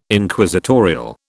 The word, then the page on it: Inquisitorial: British English